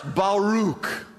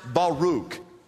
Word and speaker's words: Baruch: The first word, write this down, it's pronounced Baruch. Uh, it looks like Barak, like the name of our president, except there are two R's, and I think he has a C in that name. This is B A R A K, looks like Barak, but it's actually pronounced Baruch.